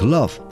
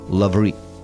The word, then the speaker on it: love: Так как «love» произносится практически через «а», а французское «love» – «мыть, стирать» звучит адекватно, в названии почти всех автоматических прачечных, вовсе не отмывающих все человеческие причуды и грехи, прячется английское «love» как дурная игра слов.